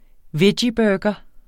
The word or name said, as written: veggieburger